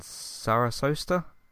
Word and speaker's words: Sarasota: Sarasota, I don't know if I've said that correctly.